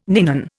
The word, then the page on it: ninon: Indian